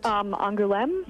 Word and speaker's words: Angoulême: You just went to the Angoulême. Is that how you pronounce it? – Angoulême.